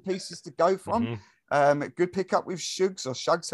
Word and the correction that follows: Suggs: however you pronounce his name. – Suggs. – Suggs.